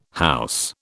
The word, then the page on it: house: British English